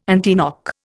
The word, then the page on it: antiknock: Indian